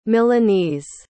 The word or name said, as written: milanese